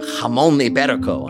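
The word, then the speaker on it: jamón ibérico: Someone in New Zealand found a leg of jamon iberico on a sidewalk in their neighborhood. Did you like that pronunciation? It's quite legit.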